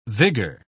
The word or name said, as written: vigor